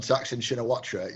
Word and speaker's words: Thaksin Shinawatra: First off, well pronounced on Thaksin Shinawatra. You said that with a newsreader confidence. Didn't even blink.